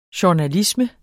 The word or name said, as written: journalisme